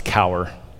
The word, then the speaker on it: Cowher: And Bill Cowher, if you're from Pittsburgh, that's how you say his name. It's Cowher.